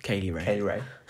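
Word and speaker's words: Kay Lee Ray: Team Ripley, which is Rhea Ripley, Candice LeRae, Tegan Nox, Mia Yim, versus Team Baszler, Shayna Baszler, Kaylee Ree. Said that wrong. – Kaylee Ray.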